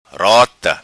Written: Rota

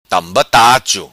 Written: Tamba Tacho

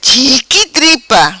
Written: Chiqui tripa

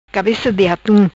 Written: Cabeca di atum

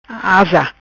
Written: Asa

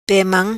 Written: Pe Mam

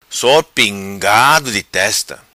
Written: Sor Pingado Di Testa

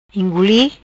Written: Inguli

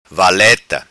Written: valeta

